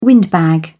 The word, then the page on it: windbag: British English